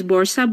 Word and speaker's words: Borsa: I want to make sure I'm saying your name right. Is it Borsa? – My nickname in NFT is Borsa, but my name is Sahar.